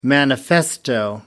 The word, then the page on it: manifesto: American English